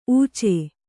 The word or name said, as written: ಊಚೆ